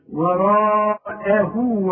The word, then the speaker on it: وراءه: عندي واو صغير بعد الضمير دي علشان تمد هذا الحرف حركتين، وراء اهو وهو الحق. أعلمك إزاي تنطق وراءه إزاي. ورأه را مدي شفايفك على هيئة قبلة والمد بتاعها كمان مفخم. وراء رجع شفايفك على هيئة ابتسامة. وراءه أخذ بالك وهو الواو هي مرققة.